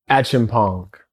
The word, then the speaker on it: Acheampong: Acheampong.